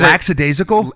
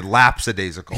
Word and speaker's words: lackadaisical: Was it lackadaisical? – And he would say lapsadaisical. – He would say lapsadaisical with a P.